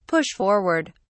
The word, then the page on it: push forward: British English